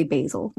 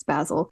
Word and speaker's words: Basil: Now, first of all, in American English, we say Basil, mm-hmm. but I know that that's not how it's said. It's Basil.